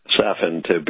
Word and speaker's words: Safin: And the villain's name is Safin, to rhyme with Satan, and they pronounce it like it's Satan. So it's interesting,